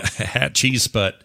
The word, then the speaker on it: Hatshepsut: Hathisput, or whatever you call her, whatever her, how you say her name is in there. The Roman guy. – Hatshepsut. – Yeah, Hatshepsut.